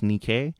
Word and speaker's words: Nikkei: I believe it's pronounced Nikkei.